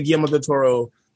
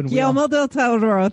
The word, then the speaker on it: Guillermo del Toro: I love Pacific Rim because it's obvious that Guillermo del Toro loves mech anime. Like he has a passion for it. – I'm sorry, you said his name wrong. – Guillermo del Toro.